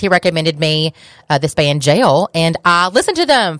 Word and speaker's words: Jale: It's a band called Jail, but it's spelled J-A-L-E, not like J-A-I-L, as in you are going to jail. But it is jail as in like a gale, like a gust of wind, but with a J.